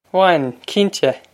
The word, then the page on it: Bhain, cinnte!: Wan, kin-teh!